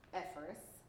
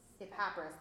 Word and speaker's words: Epaphras: Just as you learned from Epaphras, I always say his name wrong. Or is it Epaphras? – Epaphras, that's right. Thank you.